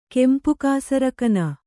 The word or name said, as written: ಕೆಂಪುಕಾಸರಕನ